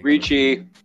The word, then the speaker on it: Ricci: Hey, we are men, and hate to see Christina, Ricky, and Jensen Ackles as sexy niggas. – Ricci. – Whatever. – Ricci. Yeah. – Put some respect. – Learn to pronounce the names.